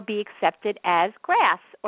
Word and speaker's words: GRAS: GRAS, which sounds like the word grass, stands for generally recognized as safe.